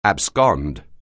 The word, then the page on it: abscond: anglais